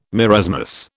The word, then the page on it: marasmus: British English